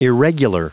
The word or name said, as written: irregular